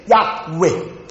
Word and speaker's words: Yahweh: 耶和华对我主说，耶和华 JYHWH，在希伯来文应当问念成 Yahweh，翻译成中文的时候 Jehovah，耶和华。那么英文有些翻译耶和华，原来是 Yahweh，为什么呢？